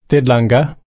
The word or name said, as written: tidlangga